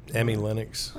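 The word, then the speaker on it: Emi Lennox: How would you say that? EMI? Emmy? Right. – Emmy Lennox.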